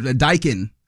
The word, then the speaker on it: Daikin: Yeah, I said it. Daikin. There's two syllables there.